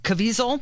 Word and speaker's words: Caviezel: Yeah, and it stars Jim Caviezel. That's how you say his name.